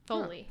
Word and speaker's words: Foley: Yeah, he puts together I think I'm saying it right. Folly. – Yes. – Follies. Yeah, he does folly work, and he composes music for stuff YouTubers. – Foley. – Yeah, Foley. He does Foley sounds.